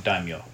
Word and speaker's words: daimyo: And so yes, it is pronounced, it's daimyo. – Daimyo? – Yeah. – Okay. – The Y actually should come after the N.